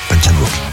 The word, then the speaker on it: Park Chan-wook: Park Chan-wook. – Plus dur. – Park Chan-wook.